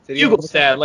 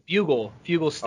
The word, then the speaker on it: Fuglestad: Fuglestad, like bugle. Fuglestad.